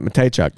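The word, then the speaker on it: Mateychuk: And something I do like about, like, Severson and Provorov, and maybe not opening the door for a Yurchek and a Matejchuk. How you say his name? I forget. – Nailed it. Matejchuk.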